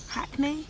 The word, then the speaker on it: Hackney: Apparently the only time I sound like I'm from Hackney is when I say the word Hackney. – Oh. – Apparently, now that doesn't even think because I actually pronounce the H.